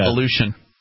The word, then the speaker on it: Aleutian: Aleutian.